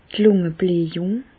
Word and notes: d' Lùngebläjùng: Alsatian; Bas-Rhin; Reichshoffen

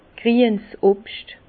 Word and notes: grien's Obscht: Bas Rhin; Herrlisheim

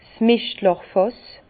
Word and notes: d' Mischtlàchfàss: Bas-Rhin; Herrlisheim

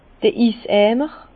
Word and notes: d'r Iseimer: Bas-Rhin; Herrlisheim

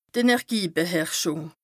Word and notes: d' Energiebeherrschùng: Bas Rhin

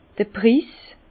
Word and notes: d'r Prïïs: Alsatian; Bas-Rhin; Herrlisheim

